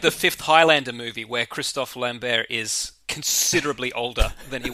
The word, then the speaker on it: Christophe Lambert: I thought it was Christopher Lambert. – Yeah, it is if you're a dumb American who can't pronounce Christophe Lambert.